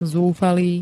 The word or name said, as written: zúfalý